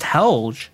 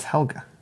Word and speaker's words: Helga: I thought it was just Helge. – No, it's Helga.